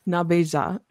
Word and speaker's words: Nabeja: And I will say in Potawatomi, it's Nabeja is how they pronounce it in that particular area.